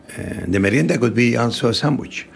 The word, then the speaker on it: merienda: How do I say that? Merienda? – Merienda. – Oh, I will say that.